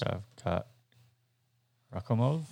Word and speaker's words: Shavkat Rakhmonov: Because he was Shavkat Rakhmonov.